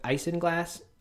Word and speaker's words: isinglass: Um, of course, the, what makes Guinness and many other beers non-vegan is I don't know if the pronunciation is right, but isinglass, I-S-I-N glass, one word, which is fish bladders, which are ground up and then used to I think clarify the beer.